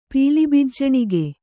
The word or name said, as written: ಪೀಲಿಬಿಜ್ಜಣಿಗೆ